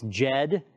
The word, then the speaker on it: Jed: It's Jed, J-E-D.